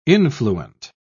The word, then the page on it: influent: British English